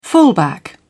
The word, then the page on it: fullback: British English